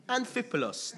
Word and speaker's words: Amphipolis: So Luke writes that when they had passed through Amphipolis, why am I not saying this right today? I've been practicing this all week and it just doesn't work. What's going on? Amphipolis, there you go.